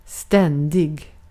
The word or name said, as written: ständig